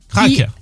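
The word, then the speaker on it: Crac'h: Ça vaut aussi pour le A-C apostrophe H. – Crac. – Voilà, alors, qui peut se prononcer AR normalement, qui doit se prononcer art, mais qui a été francisé en hack, comme pour crack, évidemment, près d'Auray, mais pas pour Pamar, par exemple.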